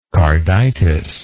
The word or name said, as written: carditic